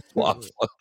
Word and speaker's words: Luftwaffe: Thank goodness with the Luftwaffe. – The Luftwaffe. Oh, you're doing well tonight. Your pronunciation is dead on.